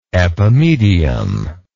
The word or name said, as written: epimedium